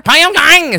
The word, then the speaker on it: Pyongyang: Pyongyang is how you pronounce it.